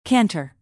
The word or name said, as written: canter